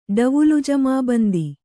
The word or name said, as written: ಡವುಲುಜಮಾಬಂದಿ